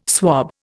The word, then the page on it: swab: Indian